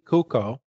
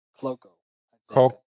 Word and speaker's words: Cuoco: Kaylee Cuoco. – Cloco. – It's C-U-O-C-O. Cuoco?